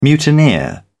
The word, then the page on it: mutineer: British English